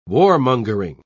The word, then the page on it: warmongering: British English